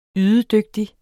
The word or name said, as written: ydedygtig